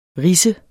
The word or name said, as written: rids